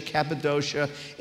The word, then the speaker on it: Cappadocia: Peter, an apostle of Jesus Christ, to God's elect, strangers in the world, scattered throughout Pontus, Galatia, Cappadocia, Asia, and Bithynia. I looked it up. That's how you pronounce those names.